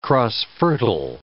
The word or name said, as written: cross-fertile